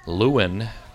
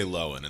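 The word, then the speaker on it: Löwen: Do you say Lewin or Lowen? It's German for lions, right? – Correct. Yeah. Well, we say Lowen in the family. – Lowen. You say Lowen. – We totally mispronounce. – Okay. – From a German perspective, it's very wrong.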